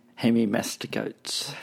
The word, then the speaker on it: Hemimastigotes: But the class of animals are the Hemimastigotes. – That's exactly the word I was looking for. And you pronounced it much better than I would have.